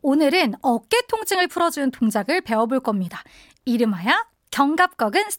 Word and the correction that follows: 견갑거근: 스트레칭. 스파르타! – 견갑거근. – 네. 어, 발음이 어려운데 음.